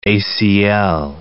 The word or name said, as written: ACL